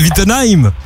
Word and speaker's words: Wittenheim: Comment ça va en direct de Wittenheim? – Oui.